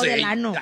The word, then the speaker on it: Delano: Pero se dice Delano, ¿no? – Yo no sé. – No, Delano.